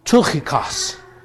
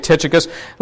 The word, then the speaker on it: Tychicus: But uh, actually, if anybody ever accuses you of pronouncing the word wrong, you can pronounce it in the Greek, which is Tychikos. Tychikos. Okay? Uh, but we say Tychicus.